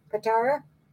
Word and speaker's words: Patara: I don't know what that'll pronounce that. – Patara.